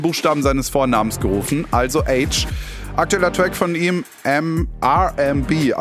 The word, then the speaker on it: Aitch: Jetzt mit neuer Musik, die kommt vom britischen Rapper H, also ausgesprochen wie das englische H, also das von TH. Ihr checkt, ne? Aber nur ein H wäre als Künstlername vielleicht zu kurz gewesen, deswegen wird es A-I-T-C-H geschrieben.